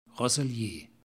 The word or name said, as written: rozelieures